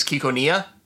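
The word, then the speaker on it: Ciconia: Yeah, well, Cyconia is supposed to be the Greek word for stork. Ryukishi did not know how to actually pronounce it, so the name is Kikonia.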